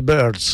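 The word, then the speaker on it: Byrds: Questo invece è scritto con la Y, perché è un vezzo che deriva dai Birds, il famoso gruppo che si chiamava Uccelli, ma non con la I, ma sempre con la Y. Quindi i Long Riders hanno preso questo vezzo dai Birds e si scrive appunto con la Y, ma si pronuncia praticamente nello stesso modo, almeno per chi ne sa poco di inglese come chi vi sta parlando.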